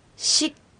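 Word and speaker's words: シㇰ: シック、そうです。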